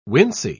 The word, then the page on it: wincey: British English